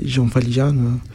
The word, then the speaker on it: Jean Valjean: Je ne sais pas si prononcé bien. Jean Valjean. – Oui, tu prononces bien.